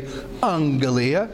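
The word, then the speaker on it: angelia (ἀγγελία): And so in first year Greek, they say usually you pronounce a double G as an NG, because it would be hard to say angelia. And so rather than stumbling over that extra G, they say pronounce the first G as an N. So we say angelia, and so we have A-N-G-L-E. E-I-A, in which we get, obviously, angel.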